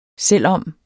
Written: selvom

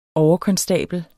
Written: overkonstabel

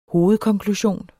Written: hovedkonklusion

